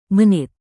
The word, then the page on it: menit: indonesian